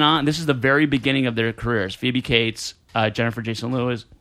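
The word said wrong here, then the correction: Leigh: Jennifer, did I say that right? – Jason Lee.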